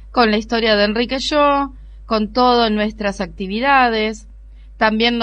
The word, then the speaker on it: Shaw: El apellido es SHAW y se pronuncia Yo.